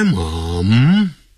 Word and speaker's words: mom: 好，一开头，my mom 就是念 mom，英国人呢会念成 m-u-m， mum mum，发呃的音，肚子呃的呃，和呃 mum， my mom is eating breakfast over there. 好，我妈妈在那边正在吃早餐，可是老美他们就会念成。 Mom, M-O-M.